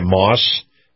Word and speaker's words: Maus: Now, again, the Truckers Highway and Byway Report is brought to you by Moss Family Automotive. Moss is spelled M-A-U-S, Family Automotive.